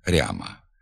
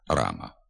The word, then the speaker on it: рама: У частині лівобережних надніпрянських та слобожанських говорів пом'якшується звук «р» перед «а» в окремих словах. Гряд, квартиря, коморя, макітря, ряма. Така вимова також є діалектною, ненормативною. Слід вимовляти «град», «квартира», «комора», «макітра», «рама».